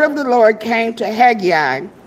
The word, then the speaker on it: Haggai: Let's go to Haggai. Did I pronounce that right, Pastor? – Haggai.